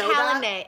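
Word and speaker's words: Calanmai: Yeah, so guys, it's calamari. – It's calamay. It's calamay. – Oh, sorry, calamay.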